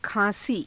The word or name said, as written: khaa~sii